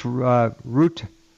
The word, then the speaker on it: Rutte: Chief Rutte. Rutte. There we go. NATO Chief Rutte. Again, it's pronounced Ruta.